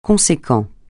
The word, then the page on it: conséquent: francais